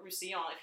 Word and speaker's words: Roussillon: She goes to the Count Roussillon, or Roussillon if you're going by the French pronunciation, but I've always heard it as Roussillon in production, so make your choice.